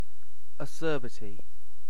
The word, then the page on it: acerbity: British English